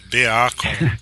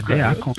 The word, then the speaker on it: Beacon: Como é que fala esse troço aqui? É Bacon mesmo? Bacon. Beacon. – É, é Beacon.